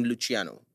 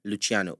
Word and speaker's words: Luciano: Find me on Instagram, Wayne Luciano. Wayne is spelled W-H-Y-N-E Luciano. L-U-C-I-A-N-O.